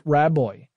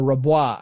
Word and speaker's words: Rabois: Also in attendance at that party was a guy named Keith Raboy, or Rabois, I guess it would be the French pronunciation.